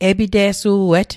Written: e'pite'su'et

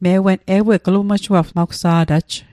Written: glmuejuapsgw

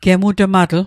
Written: gemutmatl

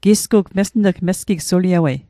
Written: mesn'g

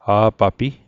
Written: a'papi

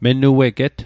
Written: menueget

